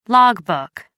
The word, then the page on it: log book: American English